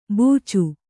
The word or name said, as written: ಬೂಚು